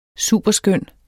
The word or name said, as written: superskøn